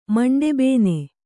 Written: ಮಂಡೆಬೇನೆ

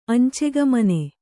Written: ಅಂಚೆಗಾಮಿನಿ

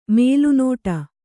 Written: ಮಲೆನಾಡು

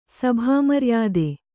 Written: ಸಭಾಮರ್ಯಾದೆ

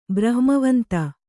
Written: ಬ್ರಹ್ಮವಂತ

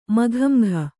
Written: ಮುಖಾಮುಖಿ

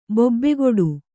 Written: ಬೊಬ್ಬೆಗೊಡು

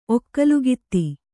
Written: ಒಕ್ಕಲುಗಿತ್ತಿ

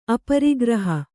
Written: ಅಪರಿಗ್ರಹ

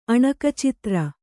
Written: ಅಣಕಚಿತ್ರ

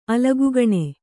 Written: ಅಲಗುಗಣೆ

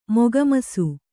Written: ಮೊಗಮರಸು

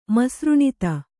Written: ಮಸೃಣಿತ

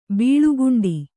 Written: ಬೆಳಿಕೊಂಡೆ

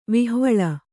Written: ವಿಹ್ವಲ

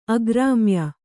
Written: ಆಕ್ರಮ್ಯ

